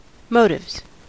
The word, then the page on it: motives: American English